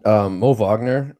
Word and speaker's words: Wagner: Yeah, and Mo Wagner, is that how it's pronounced? – Yep.